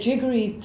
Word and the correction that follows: jaggery: I never know how to say it. That's okay for everyone to have, right? As a sugar replacement. – As a refined sugar replacement, you can go with, like, jaggery and...